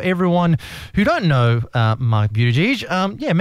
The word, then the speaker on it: Buttigieg: I hope I have pronounced your name properly, Buttigieg. – One second. That's the Maltese pronunciation. Yes. A lot of people say Buttigieg, but it's actually Buttigieg is the correct pronunciation, so well done.